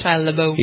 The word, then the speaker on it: Shia LaBeouf: But he also has to take shots at Shia LaBeouf. Is that how you pronounce his name? – Mm-hmm. – I never can do it right. – Shia LaBeouf.